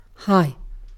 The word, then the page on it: hi: British English